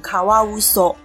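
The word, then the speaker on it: 獺: 那水獭念成卡瓦乌索，可以翻成中文叫做河里说谎的意思。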